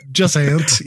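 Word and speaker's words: Jusant: Juicant. – Okay. – I think you say that. Juice Ant. In America, I don't know how to say it. Just ant, yes.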